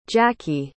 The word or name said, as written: jackie